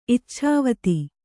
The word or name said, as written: ಇಚ್ಛಾವತಿ